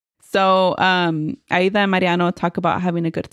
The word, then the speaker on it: Mariano: Dude, now I'm tripping because I feel like I'm going to say Marano. – Sorry, no. – You said it right. – I know I did, but in my head, it sounded like Marano.